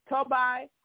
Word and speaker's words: Tobiah: Sanballat is Sanballat, and it's Tobai, and it's Geshem.